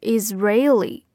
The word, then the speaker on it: Israeli: 这个地方大家首先注意一下这个单词的读音，它就是 Israeli。 Israeli 跟这个国家以色列 Israel 他们的重音是不一样的哈。